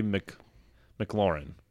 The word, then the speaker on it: McLaurin: Terry McLaurin, is that how you say it? – I think it's Terry McLaurin. – McLaurin? – I think so. I believe so.